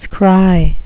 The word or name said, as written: scry